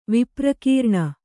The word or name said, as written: ವಿಪ್ರಕೀರ್ಣ